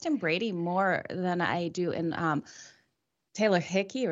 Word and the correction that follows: Heinicke: How do I say that? Heinicke. – Heinicke. – Heinicke. – I wanted to say Hickey, sorry.